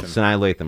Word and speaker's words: Sanaa Lathan: Sanaa Lathan. – You're going to have to spell that for me, big dog. – S-A-N-A-A-L-A-T-H-A-N. – Oh, yeah. F-A-N-N. – S-A-S-A-N-A.